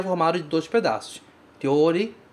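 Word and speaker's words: ryori (料理): Cuidados com esta palavra, ryori, na sua pronúncia. Primeiro, ou significa o. A gente pronuncia o. Então, ryori, ryori. Outro cuidado é esse começo, ryo, ryo, ryo. É de uma vez só. Cuidado para não transformar em ryo.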